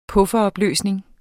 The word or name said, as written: buffer